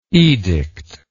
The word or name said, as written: edictally